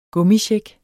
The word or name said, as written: gummicheck